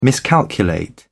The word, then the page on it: miscalculate: British English